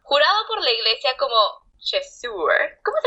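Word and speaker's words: Chasseur: Chesur. Eso. es que es francés, es un término en francés, entonces se pronuncia así. El AU se pronuncia eh, como si fueras a vomitar.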